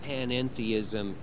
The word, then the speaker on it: panentheism: And the middle syllable, en, is very important because it differentiates it from pantheism, with which this notion is sometimes confused and wrongly identified. Let me explain what panentheism is in two different ways.